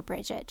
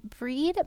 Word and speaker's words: Brigid: And some people call her Bridget, just so you know. – Okay. – This is the pronunciation that feels right to me. But Bridget is great. Breed may appear in any phase of womanhood, maiden, matron, or crone.